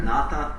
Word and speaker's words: nata: Ната, without her, nata means dancer. – Ната значит танцор, без ха произносится.